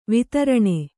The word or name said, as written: ವೈತರಣಿ